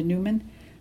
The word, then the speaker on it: Newman: Yes, Linda Newman. And Newman is spelled N-E-W-M-A-N.